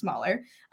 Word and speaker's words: SmHauler: So that's why the H is capitalized and it's pronounced smaller.